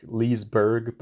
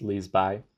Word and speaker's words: Liseberg: the amusement park, it's called Liseberg. It's like Liseberg, but it's pronounced Liseby.